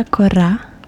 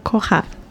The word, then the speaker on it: Carrera: So with the Italian pronunciation is Stella Corra. – Corra. – Corra. But I'm also half Cape Verdean, so in Cape Verde they call me Stella Corra.